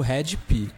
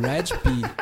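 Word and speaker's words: Hed PE: Waps, é o Red P. – Essa banda se escreve estranho o nome dela, é H-E-D-P-E, né? – É Planet Earth, né?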